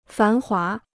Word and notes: 繁华: fán huá

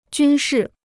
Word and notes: 军事: jūn shì

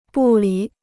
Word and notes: 不理: bù lǐ

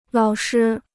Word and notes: 老师: lǎo shī

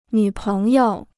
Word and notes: 女朋友: nǚ péng you

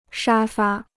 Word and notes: 沙发: shā fā

